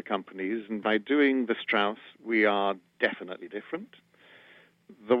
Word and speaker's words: Strauss: Or Strauss. – Thank you for pronouncing that correct.